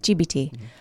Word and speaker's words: ChatGPT: It's ChatGPT. Cheap-BT? – GPT. – GPT. – P. – I can never say it right.